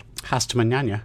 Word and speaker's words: Hasta mañana: And I was playing an ABBA song, Hasta Mañana. – Ah, yes. – Pronounce that incorrectly. – Hasta mañana. – Mañana, thank you.